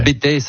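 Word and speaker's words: Bettex: Alors juste au-dessus de Saint-Gervais, où effectivement, le Bétex. Le Bétex, certains disent, prononcent pas le X, le Bétex. Enfin, il y a deux écoles, mais en tout cas une seule école de ski là-bas, et c'est là-bas où je suis allé.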